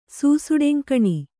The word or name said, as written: ಸೂಸುಡೆಂಕಣಿ